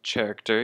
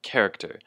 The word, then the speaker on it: character: And of course, be careful that the pronunciation here isn't character, even though it is CH, it's pronounced character. And with the emphasis on the first syllable, the ke, character.